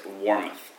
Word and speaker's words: Warmuth: Emmerich Paul Warman Harney. – Emmerich Paul, in the middle? – Is Warmuth Harney. – And how do you spell it, please? – W-A-R-M-U-T-H.